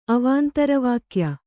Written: ಅವಾಂತರವಾಕ್ಯ

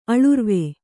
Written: ಅಳುರ್ವೆ

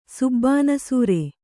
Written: ಸುಬ್ಬಾನಸೂರೆ

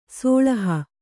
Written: ಸೋಳಹ

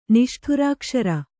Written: ನಿಷ್ಠುರಾಕ್ಷರ

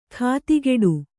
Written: ಖಾತಿಗೆಡು

